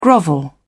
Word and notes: grovel: British English

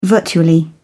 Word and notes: virtually: British English

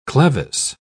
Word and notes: clevis: British English

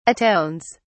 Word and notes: atones: British English

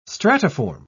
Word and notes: stratiform: British English

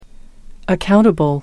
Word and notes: accountable: American English

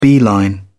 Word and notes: beeline: British English

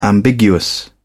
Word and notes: ambiguous: British English

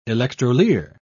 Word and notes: electrolier: British English

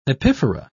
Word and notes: epiphora: British English

